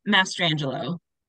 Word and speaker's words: Mastrangelo: It's close. It's okay. It's Mastrangelo.